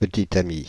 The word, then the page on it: petite amie: /pə.ti.t‿a.mi/; /pə.ti.ta.mi/